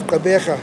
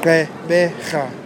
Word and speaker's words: Gqeberha: But this particular name sounds complicated because the Q is preceded by a G, and the name ends with a guttural H. So here is how it's spelled. G-Q-E-B-E-R-H-A.